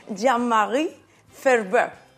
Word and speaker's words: Jean-Marie Ferbeuf: Jean-Marie Ferber.